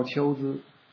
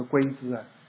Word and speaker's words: 龟兹: 打到丘孜，丘孜就是现在新疆的这个库车县，这个不念龟，也不是乌龟，也不是龟兹，是一个多音字，念秋之国。